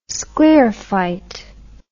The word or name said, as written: sclerophyte